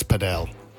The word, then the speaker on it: padel: Well, if you like squash a little bit and you also like tennis, then paddle. – Yeah, but sorry, can I just stop you? It's padel.